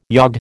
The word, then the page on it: yod: American English